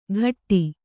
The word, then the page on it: ಘಟ್ಟಿ: ghatti